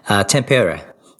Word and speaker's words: Tampere: Just checking my pronunciation for Tampere, or I'm really going to get in trouble. But yes, Tampere. There we go.